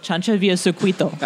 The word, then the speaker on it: Chancha Via Circuito: Chancha Via Circuito.